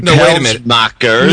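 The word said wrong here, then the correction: Pelsmaekers: Well, he was irked about this not when I pronounced his name Pelsmachers. Yes. I didn't get that. – You got to get the S in there. Yeah, Pelsmachers.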